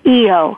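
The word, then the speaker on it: I'o: Well, the EO chant, it's I apostrophe O, and it's pronounced EO.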